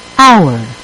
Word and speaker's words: hour: В следующем слове буква H не произносится. – Hour.